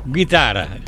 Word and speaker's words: Guitarra: Guitarra, si pronuncia così, esatto.